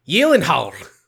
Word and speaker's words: Gyllenhaal: And so the actual legitimate correct way to pronounce his last name is like Gyllenhaal.